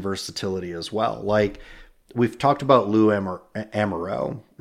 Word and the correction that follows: Anarumo: Am I saying his name right? – Anarumo. – I was going to say Lou Amarillo, and I'm like, that's not his name.